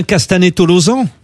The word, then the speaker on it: Castanet-Tolosan: On prononce bien Castanet-Tolosan? – C'est ça. – Ouais, oui. Et oui, parce que j'ai toujours, à chaque fois que je passe vers Toulouse, je dis Tolosane ou Tolosan, c'est toujours.